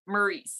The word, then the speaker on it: Maryse: Okay, so it was Miz and I never pronounced her name right. – Maryse.